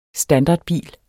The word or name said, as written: standardbil